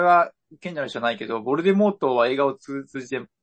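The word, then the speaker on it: Voldemort: ボルデモートの最後の T は無声なんだって。 – へえ。 – オーディオブックでは。 – はいはい。 – ボルデモーになるのかな。 – うんうん。 – でも、映画だと T は発音されてるんですよ。で、ローリング氏に聞いたら、まあ、無声の T が正しいと認めたらしいんですよね。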